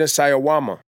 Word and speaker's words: Sawayama: Rina Sawayama.